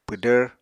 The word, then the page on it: pwdvr: Apatani